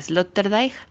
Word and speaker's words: Sloterdijk: Su nombre es Peter Sloterdijk o como otros lo pronuncian Sloterdijk.